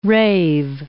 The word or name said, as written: rave